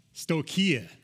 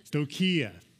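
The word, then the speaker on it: Stoichia (στοιχεῖα): I like to teach you Greek, and I'm probably going to, I should have Cliff tell me the right pronunciation, but it's stoichin. That's more like Slavic. I don't know what I said there. Huh? Stoichia. There we go. Not even close to what I said. Stoichia.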